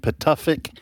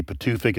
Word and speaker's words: Pituffik: The United States currently operates one military base in Greenland, Pituffik. I don't know if I'm pronouncing that right. It may be Pituffik. It's P-I-T-U-F-F-I-K.